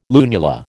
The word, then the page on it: lunula: American English